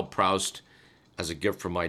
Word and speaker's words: Proust: Mr. Millen just weighed in to point out that technically it is more correctly Marcel Proust. – That's the proper pronunciation.